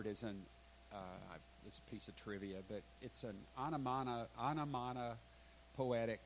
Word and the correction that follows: onomatopoeia: word. Onomatopoeia. Thank you very much. I knew I wouldn't get in a syllable there somewhere.